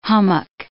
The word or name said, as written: hummock